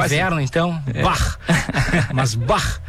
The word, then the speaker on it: Bach: Nós falamos de Bach, a pronúncia Bach, B-A-C-H, o alemão CH sempre tem esse R.